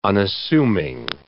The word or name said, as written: unassuming